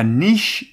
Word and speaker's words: niche: 약 24개의 다른 회사들에 의해서 spawning a niche industry of resignation outsourcing. – 오, 여기서는 난이도가 조금 높은 단어들이 들렸습니다. spawn이라는 기본적인 어휘부터 접근을 해보면 어떤 결과 상황을 나타라는 의미예요. 그리고 niche라는 발음을 들으셨는데 n-i-c-h-e라는 철자입니다. 이게 자리나 역할이나 일이나 틈새라는 명사가 되는 거죠.